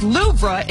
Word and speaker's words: Louvre: high on the list, along with Storm Eowyn and the Louvre Museum, which is pronounced Louvre in France.